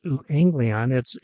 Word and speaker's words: euangelion: The Greek word for gospel is euangelion, pronounced euangelion.